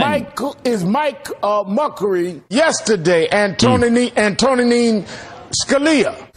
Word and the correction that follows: Antonin Scalia: Kim Kardashian. – You're talking about a Supreme Court justice he can't pronounce. Really? Antonin Scalia.